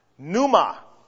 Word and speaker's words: pneuma: Pneuma. The P is generally silent in the Greek. Sometimes some Greek scholars will pronounce the P, but it's generally pneuma, N-E-U-M-A, P is silent. In the Old Testament, the word is what? Ruach, Ruach, R-U-W-A-H, or R-U-W-A-C-H, several ways to spell it, Ruach.